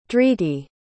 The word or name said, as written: dhriti